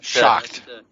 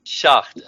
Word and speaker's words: Schacht: I don't know how you pronounce his name, Schacht or Schacht. – Schacht. – Schacht, okay.